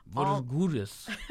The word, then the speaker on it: Morghulis: Although one running theme throughout the entire show also is Valar Dohaeris and then Valar Morghulis, which is... – Oh, nice pronunciation. – I don't even think I said that right, but it's... – Morghulis. – Valar Morghulis? – Gandules.